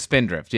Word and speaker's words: Spindrift: is it Spindrift? Is that correct? – It is Spindrift, yes.